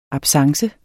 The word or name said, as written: absence